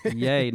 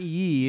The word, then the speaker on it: Ye: I'm not saying it right. Yay. Not ye. He spells it Y-E, yeah. But yay.